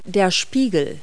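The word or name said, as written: vanity mirror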